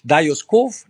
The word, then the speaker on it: Dajos Kof: Wenn du dich nochmal zurückerinnerst, als du das erste Mal in dem Hotel warst, äh, was übrigens Darius Kof heißt, ne? oder wie, wie spricht man das richtig aus? Das ist richtig. Dajos Kof, der Nachname vom Inhaber.